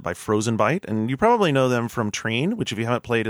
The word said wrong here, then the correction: Trine: Treen? That was Trine. – Trine.